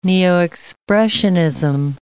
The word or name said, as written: neo-Expressionism